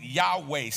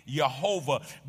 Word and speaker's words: YHWH: It appears without its own vowels, and its exact pronunciation is debated. Some pronounce it Yahweh, some pronounce it Jehovah, because it does not have any vowels connected to it.